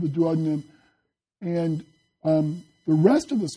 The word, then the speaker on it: duodenum: It's called the duodenum. Can you say that? Duodenum. Or you can pronounce it duodenum. Yeah. So you'll hear it pronounced both ways, duodenum or duodenum.